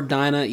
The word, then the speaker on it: Dinah: He mourns for Dinah, even though it's pronounced Dina, because she was a good one, and thinking back on last episode, he questions why the owl only let him live.